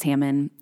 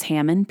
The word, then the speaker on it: Hamman: I'm Dr. Grace Hammond, and you can follow me on social media at Old Books with Grace on Instagram and at Grace Hammond, PhD on Twitter. And my last name is spelled H-A-M-M-A-N, like ham man.